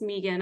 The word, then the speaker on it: Megan: So first of all, my name is pronounced Megan. I know it's kind of weird. It's spelled like Megan, but I was named after a French woman. And so it's French pronunciation.